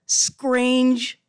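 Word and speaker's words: Skrange: Scrange Fruit. And that's S-K-R-A-N-G-E, playing on a common Bahamian pronunciation of the word strange.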